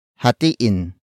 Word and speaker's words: hatiin: Word for word it's hatiin mo ito sa dalawa.